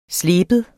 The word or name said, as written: sleben